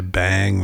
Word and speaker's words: Beyng: And I haven't said this in a while, so just a reminder, when we say bang, we are pronouncing B-E-Y-N-G, which is a respelling of being that Heidegger uses to describe a more eventful, I don't know, some different kind of being.